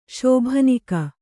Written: ಶೋಭನಿಕ

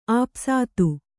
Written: ಆಪಸಾತು

